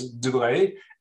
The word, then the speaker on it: Dubreuil: First of all, yes, I can correct you, it's Dubreuil, but I reassure you that anywhere I've been in the world, no one can pronounce that name except for France, so you're good.